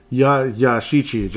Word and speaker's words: Yashichi: And apparently as you ride the pulley all the way to the right, there is that, I forget what they call it, Takeshi. I think if I'm pronouncing it correctly, let me see. I had it right in front of me here. It is the... Yashichi, just like in 1943, where in this case it fills up everything.